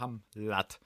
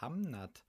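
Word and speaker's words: Hamnet: wie war Hamlat? Ich war so, Digga, das heißt Hamnat. – Ach so. – Hä, du hast das immer falsch gesagt. Ich so, du hast immer Hamlat gesagt.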